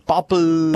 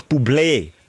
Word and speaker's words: Bublé: Es gibt Bublé, Bubble, Bubbly. Aber er hat eben ein Ägü auf dem E und heisst wirklich Bublé. Bublé. So heisst er.